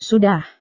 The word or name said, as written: sudah